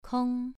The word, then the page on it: kong: 2